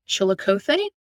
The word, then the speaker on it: Chillicothe: So in this week in 1928, in how I believe you pronounce that is Chillicothe, Missouri.